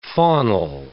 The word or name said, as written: faunal